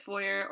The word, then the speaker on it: foyer: Just as a side note, you pronounce the word foyer. – Yeah. – Is that how you think it's pronounced all the time? – I know it's French. I know some people say it the right way, but lots of Americans, at least, just say it foyer.